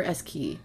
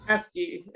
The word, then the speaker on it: Eskey: Eskey or Eskey? – Eskey. It's like a key in a lock.